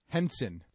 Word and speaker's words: Henson: Is it Tarji? Tarjai P. Henderson. All right. I'll stop poking. Taraji? P. Henson. – P. Henson. Okay. T. P. Henson. – Oh, it's terrible. – T. P. Henson played Benjamin Button.